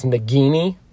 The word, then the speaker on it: Nagini: And people yelled at me because I said Nagini. And they were like, it's a hard G. It's Nagini.